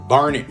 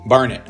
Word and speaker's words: Barnet: So my mailing address is in Barnet, Vermont. Like it rhymes with darn it. Barnet.